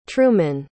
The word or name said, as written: trumen